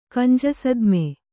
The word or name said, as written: ಕಂಜಸದ್ಮೆ